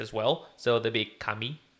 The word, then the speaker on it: Camille: But in America, we'd say Camille, but in France, they say Camille.